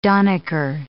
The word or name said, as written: donniker